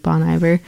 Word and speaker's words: Bon Iver: but I always just say like Bon Iver.